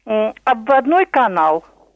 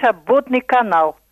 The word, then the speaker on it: Обводный канал: Вот такие ударения, например, вроде и хороший диктор называет обводной канал, имея в виду вот именно наш обводный канал, а считает себя ленинградцем.